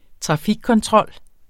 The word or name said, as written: trafikkontrol